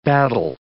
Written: battle

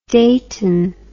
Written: Deighton

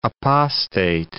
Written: apostate

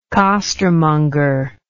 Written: costermonger

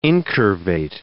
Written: incurvate

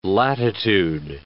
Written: latitude

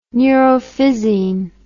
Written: neurophysin

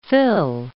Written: fill

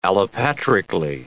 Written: allopatrically

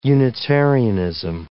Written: unitarianism

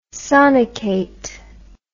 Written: sonicate

